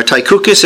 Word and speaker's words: Tychicus: Talking about Tychicus, or Tychicus as it's sometimes pronounced.